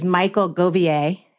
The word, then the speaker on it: Michael Govier: So our guest today is Michael Govier.